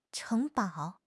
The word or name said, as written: 城堡